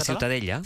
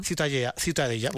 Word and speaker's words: Ciutadella: ¿cómo se pronuncia? – La Ciutadella. – Ciutadella. Bueno, pues Ciutadella también.